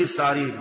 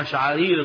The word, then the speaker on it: الشعارير: فبدل ما يقول الشعارير نطقها ليه؟ الثعارير.